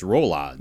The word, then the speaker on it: Rolaids: They're like, well, I don't know, you're mispronouncing it. It's Rolaids. – Yeah, Rolaids. – It's Rolaids. Rolaids.